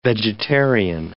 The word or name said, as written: vegetarian